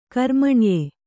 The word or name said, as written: ಕರ್ಮಣ್ಯೆ